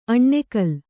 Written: ಅನಾಕುಲ

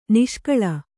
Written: ನಿಷ್ಕಳ

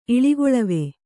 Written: ಇಳಿಗೊಳವೆ

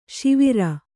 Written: ಸೌವೀರ

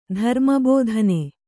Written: ಧರ್ಮಬೋಧನೆ

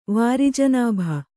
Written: ವಾರಿಜನಾಭ

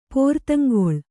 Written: ಪೋರ್ತಂಗೊಳ್

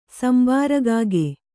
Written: ಸಂಬಾರಕಾಗೆ

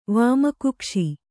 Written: ವಾಮಕುಕ್ಷಿ

